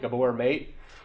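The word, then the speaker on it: Gabor Maté: Start helping them unravel because the addiction, it's there's a there's a quote from this dude named Ga- uh, Gaber Mate. I don't know how to say his name. Gaber Mate, Gabor Mate.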